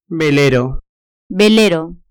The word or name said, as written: velero